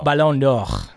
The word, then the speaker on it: Ballon d'Or: Oh, Ballon d'Or.